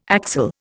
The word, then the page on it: axel: Indian